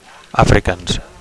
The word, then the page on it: afrikaans: Catalan